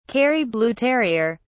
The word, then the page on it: kerry blue terrier: British English